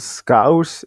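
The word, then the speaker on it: Scouse: Lapskaus på liverpoolsk heter skaus, uttales skaus I en stavelse.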